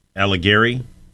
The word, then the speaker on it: Alighieri: I don't think I said that right. – Alighieri? – Yeah, Alighieri.